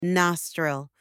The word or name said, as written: nostril